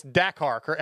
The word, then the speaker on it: Dakar: And I want to ask you so winning Dakar, the, I keep saying Dakar, it's Dakar. How do you say it pr- properly? – Yeah, Dakar. – Dakar.